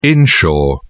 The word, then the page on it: inshore: British English